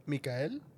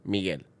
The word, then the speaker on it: Miguel: Mikael? – Miguel.